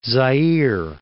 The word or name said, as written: Zaire